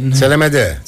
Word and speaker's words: Τσελεμέντε: Τσελεμέντε, συγγνώμη. – Τσελεμέντε, ναι. Γαλλικά θα το τονίζει.